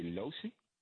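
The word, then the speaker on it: Locy: Are you trying to say Losi? – I'm just reading what they gave me, brother.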